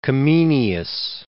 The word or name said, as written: Comenius